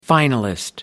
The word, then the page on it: finalist: American English